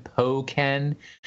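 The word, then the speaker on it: Pokkén: Did I pronounce it correctly this time? Pokken? – Yes.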